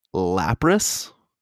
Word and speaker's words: Lapras: J-Mo, you got Lapras.